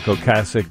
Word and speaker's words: Ocasek: My whole life I've said Rick Ocasek. Everybody today is saying Rick Ocasek because I guess that's how he pronounces it. The correct pronunciation is a soft A.